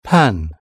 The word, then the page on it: pan: British English